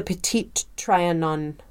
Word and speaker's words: Petit Trianon: So they're touring Versailles, and they want to find the Petit Trianon.